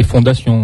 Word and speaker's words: Fondation: Sur les nerfs, c'est un premier roman traduit, donc de Larry Foundation. Voilà. – Ça s'écrit Larry comme Larry avec et Fondation, en fait. – Oui, mais je trouvais que ça faisait assez bête de dire oui, Larry Fondation.